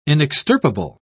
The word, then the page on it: inextirpable: British English